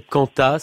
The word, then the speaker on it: Quanta: C'est quoi ces cantats, ces mélodies sur des textes de Dominique Lambert? – Alors, on prononce Canta d'ailleurs, Q-U-A-N-T-A.